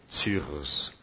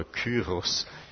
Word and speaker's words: Cyrus: Natürlich Cyrus, ja, oder Kyros. Manche Übersetzung hat andere Aussprache.